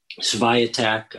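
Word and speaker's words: Świątek: You know, you have Swiatek. I'm sure I butchered that name. – No, that was actually fairly close. Yeah.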